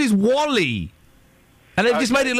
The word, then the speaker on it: WALL-E: Well, but it's pronounced Wally. Why would you call a film Wall-E? That doesn't sound like a name. You pronounce Wally.